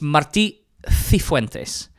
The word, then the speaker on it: Martí Cifuentes: Let's get the pronunciation elephant out of the room first, because it's Martí Cifuentes.